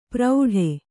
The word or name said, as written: ಪ್ರೌಢೆ